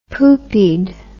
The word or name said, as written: poopied